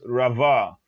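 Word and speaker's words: rava (רבה): This word is rava, and it is spelled with a resh, a bet, and a hey, pronounced rava.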